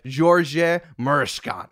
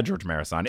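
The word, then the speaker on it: Gheorghe Mureșan: I listened to the Wikipedia pronunciation of his name, like, 17 times. So oh, George Marison? – It's like, George Marison. – Yeah, George Marison. In the 90s, they just, like, Americanized everybody's name.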